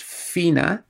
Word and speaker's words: Fiona: But in the context, it's very clear that that's supposed to be pronounced Fina.